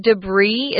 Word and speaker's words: debris: Notice here we're not saying debris or debris. It's pronounced debris. It's a French word. That's why we don't pronounce the S.